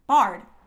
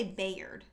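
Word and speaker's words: Byard: Bard. – Kevin Bard. – Kevin Bard. – Okay, his name is spelled B-Y, and I want to say Bayard.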